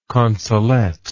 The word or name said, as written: consolette